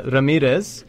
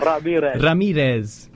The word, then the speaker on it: Ramirez: Well, firstly, kudos on the pronunciation of Ramirez. – Yeah. – No one's ever said it better. Ramirez. – Bit of roll of the R's, yeah.